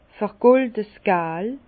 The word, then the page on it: vergoldes gal: Bas-Rhin; Herrlisheim